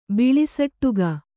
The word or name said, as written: ಬಿಳಿಸಟ್ಟುಗ